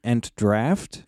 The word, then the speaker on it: Entdraught: Yeah, this is the Entdraught, or as I guess we would say it here, Entdraft. – Draft, yes.